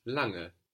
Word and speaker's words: lange: The word for long in German, long, is lange. – L-A-N-G-E. And this N-G that is falling on the end of the syllable is like the English I-N-G, lange. We don't have a hard G sound.